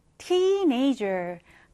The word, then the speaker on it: teenager: 자, 얘 어떻게 읽어요? teenager. 강세를 앞에 강하게 주시면 되겠습니다.